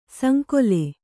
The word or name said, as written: ಸಂಕುಲ